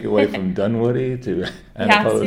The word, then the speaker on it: Antipolo: And it's in Antipolo, am I saying that right? – Yeah, city.